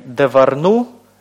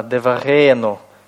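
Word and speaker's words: деварену (דְּבָרֵנוּ): Не «деварну», а «деварену».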